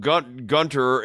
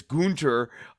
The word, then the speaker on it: Gunther: No, it's not Gunter, it's Gunter.